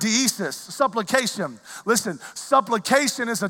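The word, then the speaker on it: deesis (δέησις): The Greek word for this is deesis.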